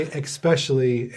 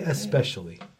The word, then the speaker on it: especially: But if you say especially and I hear you say it, I'm going to be like, don't say no, especially. Say especially. It's especially. A silent C, yeah.